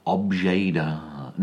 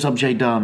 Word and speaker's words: objet d'art: He says that he only sells objet d'art. Now, I wrote that down as objet, you know, how you would say it, spell it, but D apostrophe A-R-T, you know, so it's French.